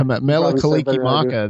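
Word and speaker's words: Mele Kalikimaka: How do you say that? – Yeah, Mela Kaliki Maka. That is the Hawaiian version of Merry Christmas.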